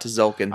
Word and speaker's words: Tzolk'in: I'm talking about Tzolk'in. – Oh, yeah. – The T is silent.